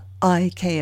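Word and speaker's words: Ik': That's spelled I-K apostrophe, but pronounced Ik.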